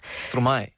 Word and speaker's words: Stromae: Een nummer van Stromae, zeg ik dat goed? – Stromae. – Stromae. – Ja.